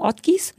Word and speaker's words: Ortgies: wie wird denn das ausgesprochen? Ortgies? – Ja. – Ich kannte die gar nicht. Möchtest du Englisch aussprechen? – Ortgies.